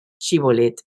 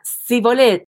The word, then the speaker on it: Shibolet: Y si ellos respondían no, entonces le decían, pues ahora di Shibolet. Y él que decía Sibolet, porque no podía pronunciarlo correctamente, le echaban mano y lo degollaban junto a los vados del Jordán.